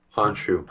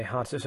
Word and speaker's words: Khonshu: how do you pronounce the god's name? Khonshu? – Honshu, Khonshu? – Silent K. – Okay, Khonshu.